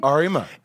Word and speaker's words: Auriemma: Auriemma.